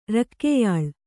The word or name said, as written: ರಕ್ಕೆಯಾಳ್